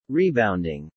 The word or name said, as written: rebounding